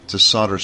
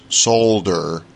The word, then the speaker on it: solder: When Mike and I first started calling the show Solder Smoke, several of our British friends wrote in and pointed out that the way Americans pronounce solder, we don't say the L. We don't say the L at all. It just comes out as solder smoke. But the British, I suppose with a more refined pronunciation here, actually pronounce the L, and it comes out as solder smoke, solder, something like that.